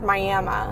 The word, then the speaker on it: Miami: It's pronounced Miami, apparently. Miami, Oklahoma.